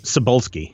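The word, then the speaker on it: Cebulski: How do you say it? Sibulski? – Sibulski. – Sibulski. – Yeah.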